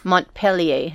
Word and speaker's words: Montpelier: Montpelier. Is that how you say it? – Sure.